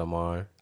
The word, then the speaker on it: Ammar: Hi, my name is Amar Adam.